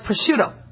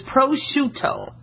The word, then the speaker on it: prosciutto: Okay, like prosciutto. Okay? A lot of times you guys are calling it prosciutto, all right? It's an Italian dry-cured ham, okay? Think of shoe and toe, so it's prosciutto, all right?